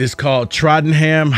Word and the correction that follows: Tottenham: Hotspur. – Tottenham. – Tottenham. – Trottenham. I mean, this is a mispronunciation buffet. Tottenham, the EPL team.